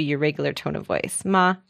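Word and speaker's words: ma (妈): First tone, the first tone is high and steady. So let's use the word ma.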